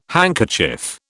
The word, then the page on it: handkerchief: British English